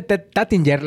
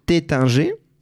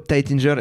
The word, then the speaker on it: Taittinger: Para tener un poquito de esa, de esa correcta pronunciación, se dice importante. – Tetanje. – Tetanje. Sin embargo, Tightinger es perfecto.